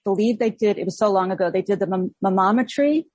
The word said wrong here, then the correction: manometry: Am I saying it right? – Yep. Manometry.